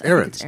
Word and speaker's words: Ahrendts: Ahrendts? – Ahrendts? – Ahrendts.